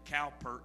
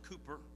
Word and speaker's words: Cowper: Say Cowper if you looked at it, but it's actually pronounced Cooper.